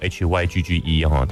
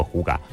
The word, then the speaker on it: hygge: 好，那什么是呼嘎呢？他其实写作 HYGGE，他不叫 Hygge，他在丹麦那边念作呼嘎。